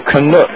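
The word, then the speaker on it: Gunook: Let me ask you, Gnuk, how do you spell that? – Gnuk.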